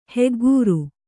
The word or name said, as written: ಹಗೆಗಾರ